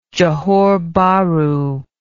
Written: Johore Bahru

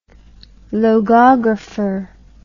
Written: logographer

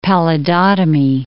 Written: pallidotomy

